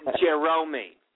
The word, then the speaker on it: Jeromy: It's Jeromy.